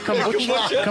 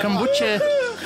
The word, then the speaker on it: kombucha: Is that how you say it? Kombucha. – Kombucha. Just, just there, you know.